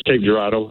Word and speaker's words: Cape Girardeau: Is that, am I saying it right? – It's Cape Girardeau and it's Missouri.